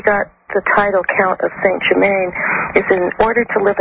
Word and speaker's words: Saint-Germain: So Louis gave him the title Count of Saint Germain, in French pronounced Saint-Germain,